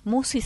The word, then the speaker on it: Musci: El canal lleva mi nombre, Marisa Musi. – ¿Cómo se escribe Musi? – Musi se escribe M-U-S-C-I.